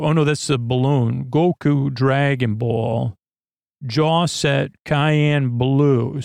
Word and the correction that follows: cyan: Cyan blue, though. It's pronounced cyan blue.